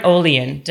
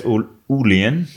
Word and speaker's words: Olien: You can say say in Norway it's Ulian. – Ulian? – But I don't we don't pronounce it. I just found that out actually. It's pronounced Olien here.